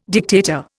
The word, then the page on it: dictator: Indian